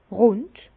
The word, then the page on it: rönd: Bas-Rhin; Herrlisheim